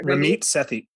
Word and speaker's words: Ramit Sethi: Ram Ramit Sethi, how do you say his name? – I forget. – Ramit Sethi. Sethi. Yeah, okay.